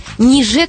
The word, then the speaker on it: ниже: Именно с таким ударением «ниже» — это старый союз со значением «даже».